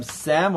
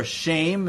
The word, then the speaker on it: Shem: Semites, we come from Sem or Shem in the proper Hebrew pronunciation, was the Shem was the great-great-grandfather of Abraham.